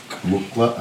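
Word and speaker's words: muqw'lha: Mukla or mukla? – Mukla. – Mukla? – Mukla. – Mukla. – Mukla. Mukla. No, no. Mukla. It's QW apostrophe. Mukla. See, everyone, we grew up saying it wrong.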